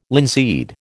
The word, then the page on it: linseed: American English